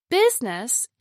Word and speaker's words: business: Business is pronounced with two syllables, business, instead of three. The I vowel sound is dropped. You do not want to pronounce it as busyness. That is another three-syllable word, busyness.